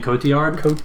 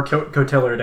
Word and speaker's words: Cotillard: Marion Cotillard? That's it. – Cotillard? – Or Cotillard, I guess, depending on who you are. – Cotillard. – If you're from Indiana. Like Marion Cotillard.